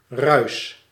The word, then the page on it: noise: /nɔɪz/